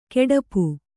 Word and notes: ಕೆಡಪು: keḍapu

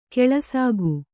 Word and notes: ಕೆಲಸಿಗ: kelasiga

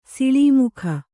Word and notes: ಸಲ್ಲಾಮುಗೈ: sallāmugai

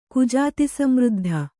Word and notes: ಕುಜಾತಿಸಮೃದ್ಧ: kannada

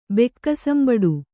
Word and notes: ಬೆಕ್ಕಸಂಬಡು: kannada

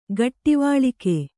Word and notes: ಘಟ್ಟಿವಾಳಿಕೆ: ghattivāḷike

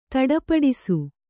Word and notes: ಧಡಪಡಿಸು: dhaḍa paḍisu

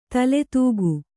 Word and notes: ತಲೆದೂಕ: Kannada; taledūka

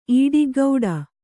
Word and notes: ಈಡಿಗ್ಗೌಡ: īḍiggauḍa

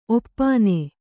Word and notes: ಒಪ್ಪಾನೆ: oppāne

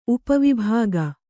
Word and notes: ಉಪವಿಭಾಗ: upa vibhāga

